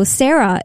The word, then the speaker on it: Sera: And that's because my name is spelt with an E. So Sarah is spelled S-E-R-A.